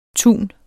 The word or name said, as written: tun